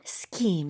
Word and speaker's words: Scheme: S-C-H-E-M-E. – 오, 잘못 보면 스팀 이렇게 잘못 읽을 것 같아요. – 맞아요. 발음은 scheme.